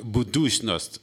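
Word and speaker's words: Budućnost: Det udtales vist nok budusnost, yes.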